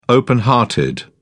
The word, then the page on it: open hearted: British English